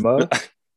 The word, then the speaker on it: Momoa: Yeah, Jason Momoa.